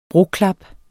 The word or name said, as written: broklap